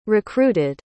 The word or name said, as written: recruited